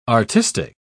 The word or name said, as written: artistic